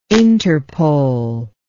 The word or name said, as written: Interpol